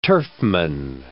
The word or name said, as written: turfman